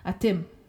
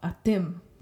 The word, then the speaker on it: atim: So in two syllable words, the stress falls on the last syllable, always. All right. So for example, atim, which is dog, atim, two syllables, you get the emphasis on the last syllable.